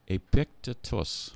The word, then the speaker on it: Epictetus: Epictetus. – Yes. – E-P-I-C-T.